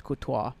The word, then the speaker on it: Courtois: And Thibaut Courtois, the Belgian keeper. I don't think I said his name. No, it's Courtois.